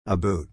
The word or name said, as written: aboot